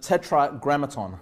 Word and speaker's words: tetragrammaton: Now, that's known as, the tetragrammaton. I feel like I'm pronouncing that wrong. No, that's the right way to pronounce it.